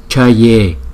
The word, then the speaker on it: Chaye: Chữ J này đọc là Cha. Chaye Chaye Mukhe Mukhe Mukhe.